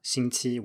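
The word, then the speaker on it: Xingqi (星期): So if you take a word like xingqi, which means week, uh, that is the standard pronunciation in uh, mainland China, Xingqi with two first tones. But in Taiwan, the standard pronunciation is Xingqi with a rising tone on the second syllable.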